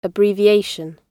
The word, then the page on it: abbreviation: əbriːvɪ’eɪʃ(ə)n; British English